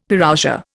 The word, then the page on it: Parousia: Indian